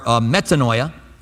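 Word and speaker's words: metanoia (μετάνοια): The Greek word, this is much easier to pronounce, is netamoia or metanoia. Metanoia. I say it's easy to pronounce and then I butcher it.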